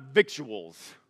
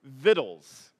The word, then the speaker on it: victuals: And this just gives me a bad attitude about so many educational things right here, but the word is actually pronounced "vittles," b-i-t-t-l-e-s is what it sounds like.